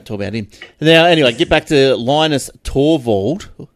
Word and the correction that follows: Torvalds: Oh, is that how you pronounce his last name? Linus Torvalds.